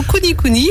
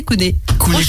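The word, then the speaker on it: kunekune: Alors j'ai deux prononciations, Kuni Kuni ou alors ce que tu me disais, Kune Kune. – Kune Kune. Mais, ouais, mais j'aime bien cochon Kune Kune.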